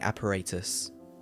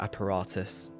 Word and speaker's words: apparatus: S-C-U-B-A stands for Self-Contained Underwater Breathing Apparatus, or apparatus. You can pronounce that two ways.